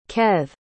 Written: kaethe

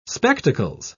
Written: spectacles

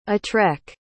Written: atrek

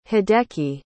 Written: hideki

